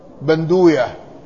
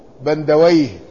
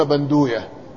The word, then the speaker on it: بندوية: واسم أبي جميلة بندوية، هكذا عندكم، يعني منهم من ينطقه بندويه، لكن على قاعدة المحدثين أنهم لا يستخدمون ويه ولا ينطقون ويه، ولذلك ينطقونه بهذا النطق، يقولون بندوية، ويقال زريبة، يعني قيل اسم أبيه بندوية وقيل زريبة.